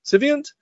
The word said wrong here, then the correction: Saviynt: Uh, Saviynt. Saviynt. Saviynt. Saviynt. My apologies.